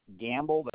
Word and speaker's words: Gamble: First name is Robert, R-O-B. E-R-T, Gamble, that's G-A-M-B-L-E, just like gambling.